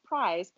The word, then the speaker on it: Pryes: Anyways, it's Prize, but it's spelled P-R-Y-E-S. So I immediately want to be like, Prize? Like, I'm not very good, but it's supposed to be Prize, I guess.